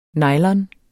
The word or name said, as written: nylon